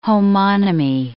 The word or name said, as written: homonymy